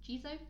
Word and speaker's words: Jizo: Once again, Japanese pronunciation, Jizo.